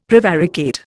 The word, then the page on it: prevaricate: Indian